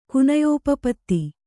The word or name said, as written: ಕುನಯೋಪಪತ್ತಿ